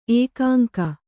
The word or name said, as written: ಏಕಾಂಕ